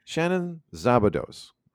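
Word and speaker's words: Shannon Szabados: Shannon Zabados. Hoping I'm pronouncing her name correctly. – Yep.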